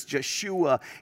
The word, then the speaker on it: Yeshua: Jesus, Yeshua, in Hebrew is how you pronounce that.